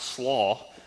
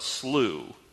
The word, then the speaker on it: Slough: Being a southerner, I have no sense of how to properly pronounce English words, and so I went to Merriam-Webster's dictionary, and they said the proper way to pronounce this is not slaw, but slew.